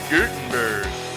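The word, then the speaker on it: Göteborg: Gutenberg. That's how you say it. You don't say that it's good. G-O is gay. Gutenberg. – Okay. – It's the O with the dots.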